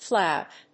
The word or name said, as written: flout